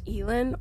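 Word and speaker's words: Alain: Then we have Roland and his two friends, Alan, although I think it's pronounced Elan or Elan. It's different in the audiobook, but Alan, Cuthbert, and Roland.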